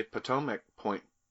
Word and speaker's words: Potomac: So your most recent series, the Potomac Point.